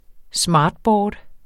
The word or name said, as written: smartboard